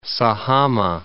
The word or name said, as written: Sajama